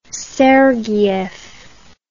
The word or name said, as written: sergiev